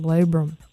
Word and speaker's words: labrum: the labrum.